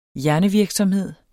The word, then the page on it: hjernevirksomhed: dansk